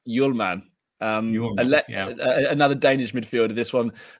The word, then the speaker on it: Hjulmand: You won't speak or pronounce the H. So. It's kind of like if you listen to the radio when they talk about Pierre-Emile Højbjerg, they always say, I mean, they get that pronunciation right. They don't say Bjerg or something like that. So, but you don't speak the H, and you say Hjulmand.